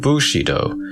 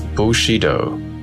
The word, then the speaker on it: Bushido: The modern Chinese pronunciation is Wu Shi Dao. In Cantonese, Mushido, very similar to Tang pronunciation, whence the Japanese Bushido.